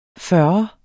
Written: fyrrer

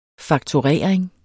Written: fakturering

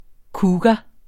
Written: cougar

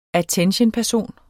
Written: attentionperson

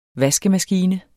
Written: VASKEMASKINE

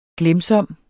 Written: glemsom

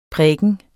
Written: præken

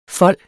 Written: fold

